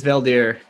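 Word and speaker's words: Veldheer: Yeah, he's the guy that did I say his name was, right? Veldier? – Yeah, it's Veldier.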